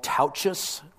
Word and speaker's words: Tautges: His name is Paul Tauches, rhymes with couches, but it's spelled T-A-U-T-G-E-S.